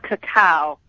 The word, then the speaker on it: cacao: Hey, well, first of all, it's pronounced cacao.